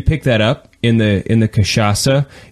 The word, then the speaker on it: cachaça: which is called cachaça. Is that how you pronounce it as well? – That is how it's pronounced, yes. – Okay, good. So cachaça is a Brazilian rum.